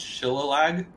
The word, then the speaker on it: Shillelagh: Sure, it's pronounced Shillelagh.